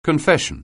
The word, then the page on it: confession: anglais